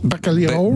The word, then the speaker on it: bacalhau: Donc, on va parler du bacalao à Brasse. C'est bien prononcé, là, ça c'est le L est mouillé, c'est liao. – Bacalhau.